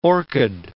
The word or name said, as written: orchid